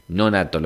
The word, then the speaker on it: Nonato: Así que hoy es el día de San Ramón Donato. Nonato, lo digo bien.